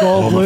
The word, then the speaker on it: Goldblum: Jeff Goldblum.